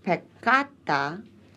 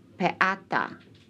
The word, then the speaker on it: peccata: Peccata is thus pronounced peccata, and not peata. Tuum is pronounced tuum. T-O-L-U-M and not tuum. T-O-O-M.